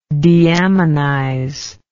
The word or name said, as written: deaminization